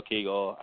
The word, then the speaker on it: Keagle: So follow me on Twitter at Bill Kegel, BILLKEAGLE, and that's BILL as in Bill, the normal way to spell it, KEAGLE. That's maybe I should say it Bill K Eagle.